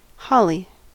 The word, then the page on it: holly: American English